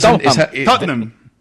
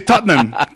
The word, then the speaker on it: Tottenham: Enséñanos, porque es que es Tottenham. – Tottenham. – Son dos sílabas. – Es difícil, ¿no? – Nosotros los británicos. Pero... – ¿Solo dos sílabas? – Sí, Tottenham.